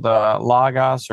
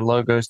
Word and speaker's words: Logos: I'll tell you what, there's a big Bible program called Logos or Logos, depending on how you want to pronounce it.